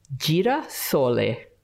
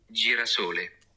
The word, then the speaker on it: girasole: Girasole. – Girasole. – Girasole. Or let's ask my new boyfriend, for his correct pronunciation. How do you say sunflower in Italian? – Girasole.